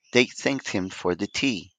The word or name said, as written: thanked